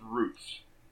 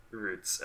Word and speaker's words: Ruth: So his name is spelled Ruth, but it's not pronounced that way. It's actually pronounced Roots. – Roots. Okay. – Yeah, like the television series.